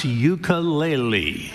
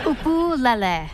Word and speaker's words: ukulele: Peter's first question to Taimane: how the hell do you pronounce ukulele? – Ukulele.